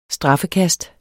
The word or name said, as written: straffekast